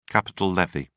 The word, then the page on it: capital levy: British English